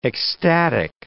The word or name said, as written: ecstatic